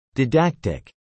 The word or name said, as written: didactic